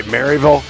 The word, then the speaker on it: Maryville: That's the way it's pronounced, right? Maryville? – Maryville, Arizona.